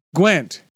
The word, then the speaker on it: Gwent: Gwent? What is this? – Gwent.